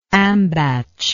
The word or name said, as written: ambatch